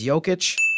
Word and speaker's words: Jokic: But then the player vote, you see that, and you see that it was Jokic won.